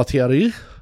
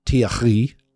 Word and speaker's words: Thiare: Jamal Thierry, as I need to pronounce it correctly, as Sarah has taught us, Jamal Thierry, I think that's right, made his debut, came on for like 12 minutes.